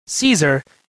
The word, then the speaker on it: Caesar: Thus, his name is pronounced in a similar way to the pronunciation of the German Kaiser. Clearly, this German name was not derived from the Middle Ages ecclesiastical Latin, in which the familiar part Caesar is Caesar, from which the modern English pronunciation, a much softened Caesar, is derived.